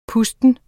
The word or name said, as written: pusten